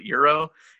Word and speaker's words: gyro: I, I don't know if it's gyro or euro or I don't know. – I believe it's pronounced euro.